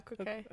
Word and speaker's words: croquet: Is that how you say that? – Yeah, croquet. – Okay. I'm just making sure that saying it right. – You're good.